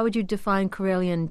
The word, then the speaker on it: Karelian: Is that, am I saying that correctly? Karelian tradition? – Karelian, yeah.